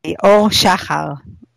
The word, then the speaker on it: Aur Shahar: Aur Shahar would be the right pronunciation, but it's very hard, I know.